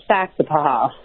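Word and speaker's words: Saxapahaw: Yeah, but we say it's Saxapaha. So you the emphasis is at the beginning, so you can roll through all those A's but still say them.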